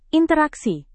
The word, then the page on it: interaksi: indonesian